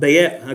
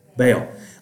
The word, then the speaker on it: Baal: Okay, how do you say, somebody tell me, how do you say B-A-A-L? Baal. Okay, because I've heard it in two syllables. Baal. So I'm from the South, and we like to put as much syllables in a word as we can, but I can't get two out of Baal, all right?